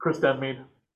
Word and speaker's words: Denmead: Denmead.